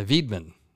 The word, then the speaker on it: Weidman: And it would be, is it a W? Because it would be pronounced with a V. – A Weidman.